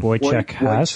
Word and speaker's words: Wojciech Has: Um, Wojciech Has.